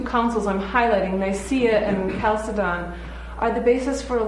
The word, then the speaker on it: Chalcedon: Then at the Council of Chalcedon, you can also pronounce it Chalcedon, in 451, two natures were affirmed to be present in Jesus.